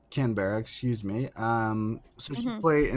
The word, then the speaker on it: Canberra: Did you say Canterbury? – Canterbury. How do you say it? – Canberra. Don't insult our neighbors to the south.